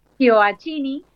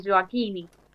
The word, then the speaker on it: Gioacchini: Es Gioacchini, o en tano como en realidad es Gioacchini.